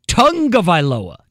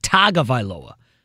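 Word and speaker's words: Tagovailoa: Uh, he was just helping me how to pronounce Tua Tungavailoa, not Tagavailoa. Doesn't sound like it's spelled.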